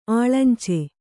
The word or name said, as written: ಅಳಂಚು